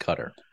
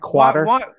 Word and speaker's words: Qatar: And in my opinion, it should be pronounced Qatar. – Well, and so. Also kind of have a problem with. – Not quarter.